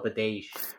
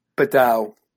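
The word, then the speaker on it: Badelj: But again, a guy like Struman, who's slow, has bad knees, a guy like how do you say it? Badel or Badej? – Badel. – Badel, right? Okay, yeah, the J is silent.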